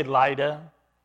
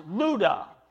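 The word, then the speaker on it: Luda (Λύδδα): But it's an upsilon. And that accent mark there, that's pronounced Luda. So he went to Luda.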